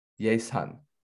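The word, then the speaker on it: yesan (예산): No, it's pronounced yesan. – Yesan. – Yesan is budget.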